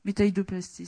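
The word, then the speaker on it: métaïdoïoplastie: métidioplastie qui est une opération alternative. Enfin, je ne sais pas comment on dit en français. Métaïdoplastie, excusez-moi, j'ai écorché.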